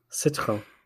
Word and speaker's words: Citroën: Citroën. Mercedes. – What did you just say? Yeah, we got Mercedes. – What is it? Citroën. – Citroën.